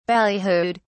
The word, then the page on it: ballyhooed: British English